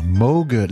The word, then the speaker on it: Moog: Mais ben oui, euh, il, était comme moi, euh, Robert Moog, parce qu'on doit dire Moog, like Vogue, comme il aimait le préciser.